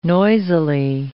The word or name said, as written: noisily